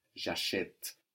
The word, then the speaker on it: j'achète: So that E that can be totally disappeared in acheter now becomes clear and pronounced, and it has a written accent on it. J'achète.